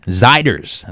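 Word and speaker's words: Zeiders: I finally, for some reason, I never thought about this, but I always say, is it Ziders? How hot the beats? I finally did a little search into the Google, and it said it rhymes with spiders. So it's Warren Ziders, and that song called Pretty Little Poison.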